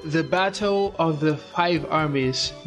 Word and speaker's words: the Battle of the Five Armies: The, the Battle of the Five Armies.